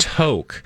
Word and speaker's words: toque: I'm playing the pronunciation. It's toke. – Toke. – A toke is a small hat. Now, Google toke, please. T-O-Q-U-E.